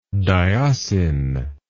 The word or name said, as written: dioscin